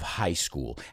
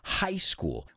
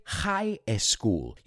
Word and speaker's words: high school: And I want to stop 1 second to look at the pronunciation. High school. We don't say high school. You see how some students of mine, they put an E before that S and they say high school and they add an extra syllable when really it's like saying the word, here's a trick. It's like saying the word highs and then cool. High school. High school, high school.